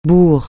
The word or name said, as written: bourre